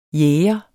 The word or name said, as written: jæger